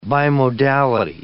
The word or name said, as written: bimodality